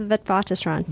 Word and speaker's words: Witwatersrand: Now, just because I did screw up the name of your university, can you say it properly for me? – Sure. It's the University of Witwatersrand.